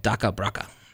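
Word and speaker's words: DakhaBrakha: It's called Daka Braka. – It is. You said it. – Did I say that right? – Yeah, you said it right.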